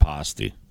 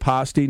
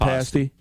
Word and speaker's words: pasty: Yeah, we pronounce that pasty. – Is it pasty, not pasty? – Yeah.